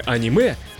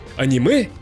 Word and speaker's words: аниме: Распространено два варианта произношения – «аниме» и «аниме». Однако, если следовать орфоэпическим правилам русского языка, то ударение ставится на последний слог, как в словах макраме, резюме, реноме, карате.